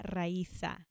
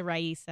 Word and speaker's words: Raiza: It's Francia Raiza. – So I say it right, but I just don't have, I can't do the R. – You can say Raiza.